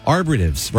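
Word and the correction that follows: arborvitae: right? And we're professional radio people. We should know how to say it, right? – Right. – But my wife, the green thumb gardener plant manager, arborvitaes. – Arborvitaes. – Arborvitaes. Like arbor, like a tree is arbor.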